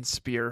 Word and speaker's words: Spear: The BSC secretary is Marianne Spear. Her last name rhymes with cheer, yeah. – not crier.